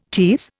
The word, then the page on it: tease: Indian